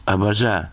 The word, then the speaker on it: абаза: Ответ был однозначным – на третьем, то есть «абаза».